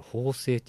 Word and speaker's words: 砲聲: 法制って読むんや、うん、あの大砲の方に「声」っていう字の難しい字ね